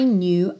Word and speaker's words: knew: Knew, o K é mudo e tem um biquinho que é quase francês.